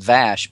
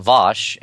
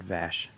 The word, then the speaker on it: Vash: Well, I was just, because that's, you know, he created the character of Vosh, who actually, he meant to have it pronounced Vash because it was named after a friend of he and his wife's, Susan Vash, who was a casting director. And she pronounces her name Vash, but of course in Patrick's Brit, it comes out Vosh, and that's what it got branded. And everyone said Vosh after that.